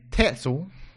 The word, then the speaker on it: Tetzel: And so in the town next door, the Luther, he was in Wittenberg at this time, you had a guy named Tetzel, whose name rhymes with pretzel.